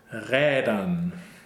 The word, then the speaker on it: Rädern: But in the next verse, we have Rädern. – So we have, it's a long A umlaut sound, because there's only one consonant, and this A vowel is not, it's not necessarily just like gelernt, right?